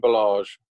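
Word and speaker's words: Ballage: So I'm assuming that they will be leaning on, is it Balazs? Is that how it's pronounced, mate? You're normally good with names? – Kalen Balazs. Yeah.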